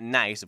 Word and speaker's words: Nice: So he goes online and he does some research and finds that it's not nice, of course, not the English pronunciation of just the word nice, but no, it's Nice, the French city